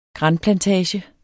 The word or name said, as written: granplantage